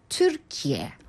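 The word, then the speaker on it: Türkiye: 트르키예. – 네. – 와. – 한국어에는 무조건 그 의이로 들어가잖아요. 네. 우리는 그냥 붙여서 바로 트르키예. – 아, 그럼 트르키예가 아니라 트르키예인 거예요.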